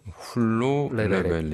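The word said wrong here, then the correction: Hulló levelek: 레베레크. 훌로 레베레크인데요.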